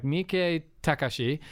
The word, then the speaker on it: Miike Takashi: So it's like Miike Takashi is how you pronounce it,